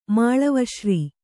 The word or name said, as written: ಮಾಳವಶ್ರೀ